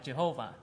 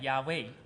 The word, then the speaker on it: Yahweh: Because in the language of Hebrew, it's not.The phonetic doesn't sound like Jehovah. – 而它的发音呢、啊，如果按照字啊跟啊子音跟母音来发音的话，应该翻译成亚威。 – According to the Hebrew language, it actually sounds like Yahweh.